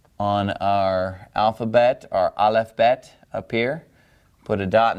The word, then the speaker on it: Bet: And this letter is called Bet. Bet. Now, if we were to remove the dot from the letter Bet, because the letter Bet makes a B sound, b-b-b-bet, all right?